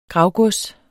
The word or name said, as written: gravgods